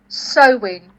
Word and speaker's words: Samhain: Sorry, so did you say the festival of Samhain? – Samhain. – Oh, Samhain. – Samhain, but you spell it Samhain. – Okay. – Right, so okay, but it's pronounced Samhain.